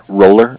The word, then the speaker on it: Roller: It's just my first name at RMS, Randy Mary Sam, Roller, R-O-L-L-E-R, Grinder, G-R-I-N-D-E-R, .com.